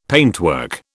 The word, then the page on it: paintwork: British English